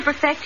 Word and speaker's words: perfect: In order to perfect his invention, Thomas A. Edison spent many hours in tedious toil. – The word is perfect. Accent on the first syllable always, whether an adjective or a transitive verb.